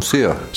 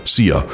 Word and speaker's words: Szia: Also schreibt man S-Z-I-A. – Genau. – Ja. Ist nicht so wie die Engländer, die sagen see you und im Dialekt sagen sea, ja, die Australier und die Cockney-Engländer, sondern sia.